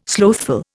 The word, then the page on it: slothful: Indian